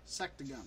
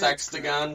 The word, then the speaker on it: sectagon: Sectagon. – We're gonna say sextagon. It no, it's not sextagon.